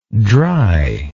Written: dryness

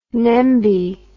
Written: nembie

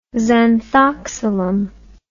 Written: zanthoxylum

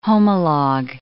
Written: homologue

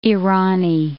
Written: Irani

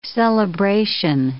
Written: celebration

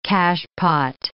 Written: cachepot

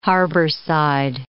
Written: harborside

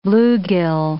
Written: bluegill

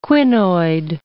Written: quinoid

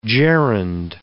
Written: gerund

